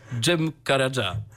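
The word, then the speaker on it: Cem Karaca: Tak, a zatem posłuchamy teraz utworu Dżewiza Dż, a wykona go dla was Dżem Karadża.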